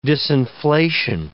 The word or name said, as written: disinflation